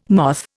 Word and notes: moth: Indian

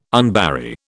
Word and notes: unbury: British English

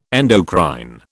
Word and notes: endocrine: British English

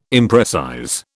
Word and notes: imprecise: British English

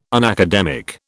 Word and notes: unacademic: British English

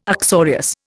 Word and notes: uxorious: Indian

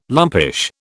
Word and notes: lumpish: British English

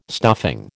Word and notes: stuffing: American English

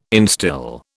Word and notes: instil: British English

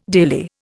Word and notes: daily: Indian

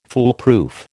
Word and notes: foolproof: American English

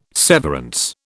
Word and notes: severance: British English

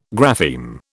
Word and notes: grapheme: British English

